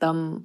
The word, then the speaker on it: Tam: Yeah, I'm like Tam like ham. – Yeah. – Always. But yeah, my name's actually pronounced thumb, like thumb, like a thumb.